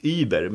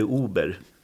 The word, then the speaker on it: Uber: det blir lätt att man sätter tyskt Uber, men det heter Uber.